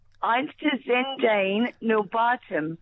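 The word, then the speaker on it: Einstürzende Neubauten: Einstizendane Nobatum.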